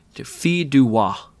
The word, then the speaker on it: fille du roi: So the king's daughter, or in French, it's pronounced fille du roi.